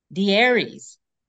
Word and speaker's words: De'Aires: De'Aires.